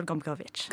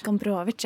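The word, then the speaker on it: Gombrowicz: Kan du si det en gang til? Gombrovic? Eh, ja, Vital Gombrovic. Gombrovic, ja.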